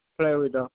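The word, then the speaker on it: Fleuridor: It's Alibeth Florida. – Okay, can you spell that last name for me? – It's F L E U R I D O R.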